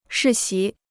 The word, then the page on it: 世袭: shì xí